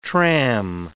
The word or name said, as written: tram